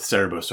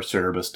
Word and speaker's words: Cerberus: But speaking about hellhounds particularly, these can kick as far back as ancient Greece with Cerberus, or Cerberus, depending on how you want to pronounce that.